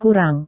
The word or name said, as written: kurang